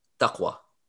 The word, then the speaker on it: taqwa: Taqwa. So T-A-Q-W-A, I wanna say.